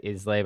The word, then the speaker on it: Islay: I always said Islay, but I think it's Islay or Isle something.